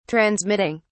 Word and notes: transmitting: British English

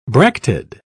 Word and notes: bracted: British English

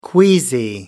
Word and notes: queasy: American English

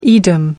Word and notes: Edam: American English

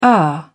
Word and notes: er: British English

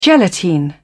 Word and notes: gelatine: British English

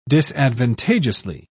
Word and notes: disadvantageously: British English

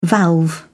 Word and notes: valve: British English

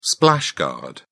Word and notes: splash-guard: British English

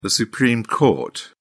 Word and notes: supreme court: British English

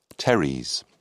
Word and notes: teres: British English

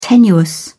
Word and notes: tenuous: British English

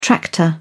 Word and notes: tractor: British English

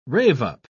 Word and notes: rave-up: British English

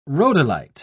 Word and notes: rhodolite: British English

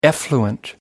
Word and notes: effluent: American English

